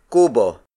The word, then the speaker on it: kubo: And then finally, we have kubo. Kubo. Kubo. Kubo is spelled as K-U-B-O.